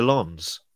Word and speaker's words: Lens: Racing Club de Lens.